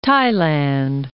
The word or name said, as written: Thailand